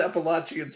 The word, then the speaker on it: Appalachian: And they all say it's Appalachian. Okay, so I learned it's Appalachian State. So that's the correct pronunciation, and you're welcome.